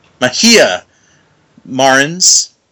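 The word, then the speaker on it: Mojica Marins: That's a soft J. – Mejia Marins,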